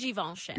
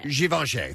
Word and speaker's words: Givenchy: Is it was Givenchy? – What is it? Givenchy? Does anybody know? I say it wrong all the time. – It's Givenchy. – Givenchy. Thank you. – I say Givenchy.